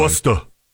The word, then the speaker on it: Worcester: A city council meeting is underway, and a man went to the, I'm going to try to say this right, Worcester City. – Yeah, they pronounce things weird. – Yeah, Worcester.